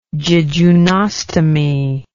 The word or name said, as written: jejunostomy